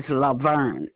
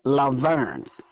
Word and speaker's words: Laverne: My first name is Laverne, L-A, capital V-E-R-N-E, Laverne.